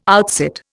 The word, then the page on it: outsit: Indian